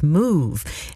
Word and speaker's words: Smoovee: And before that, Vel Lewis and Marion Meadows teamed up to bring us Smoove. And you have to say it like that, because it's spelled S-M-O-O-V-E-E.